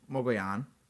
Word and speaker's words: Mogollon: The name Mogollon comes from, or Mogollon, it's spelled M-O-G-O-L-L-O-N, so I did look that up for you.